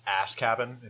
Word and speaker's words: Azkaban: It's Azkaban, if you could say it right. I would appreciate it.